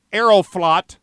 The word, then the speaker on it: Aeroflot: And he was distraught when the Russian airline Aeroflot, I'm sure I'm not pronouncing that in Russian properly. – No, I think he nailed it.